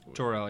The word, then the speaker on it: Jor-El: But he plays, if I say the name right, Jor-El, right? – Jor-El, yeah.